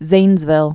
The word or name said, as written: Zanesville